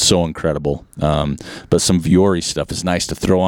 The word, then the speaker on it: Vuori: And I'm hoping I'm saying it right. It's V-U-O-R-I. It's been around. I have it. It's Vuori. They're Ponto pants and they're Strato Tech shirt.